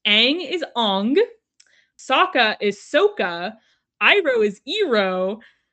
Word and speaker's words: Sokka: And then my final fun fact for us is in the dialogue between Han and Sokka, Han pronounces Sokka's name incorrectly by elongating the O, which is the Asian pronunciation and how it is pronounced in the film The Last Airbender.